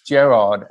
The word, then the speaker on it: Gerard: It was like, all right, when I came up with Gerald or Jer- Jerry, and I said, no, it's Gerard.